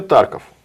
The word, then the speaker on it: Тарков: Тарков, Краков, есть примерно подобные как бы названия городов, которые звучат вот так вот, с ударением на первый слог. Потому что иначе как-то звучит неестественно.